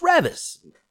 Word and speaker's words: Reavis: Because it's spelled the same, but it's pronounced Reavis.